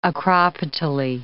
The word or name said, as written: acropetally